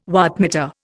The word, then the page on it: wattmeter: Indian